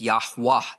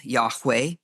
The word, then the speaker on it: Yahweh: And upon the basis of that, it could be that we pronounce the name as Yahweh with an A at the end.